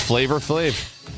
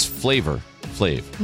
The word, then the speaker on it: Flavor Flav: It is Flavor Flav. – That's so weird. That's like it's Little Wayne. It's not Little Wayne. – It's not spelled like F-L-A-V-A. It's Flavor Flav.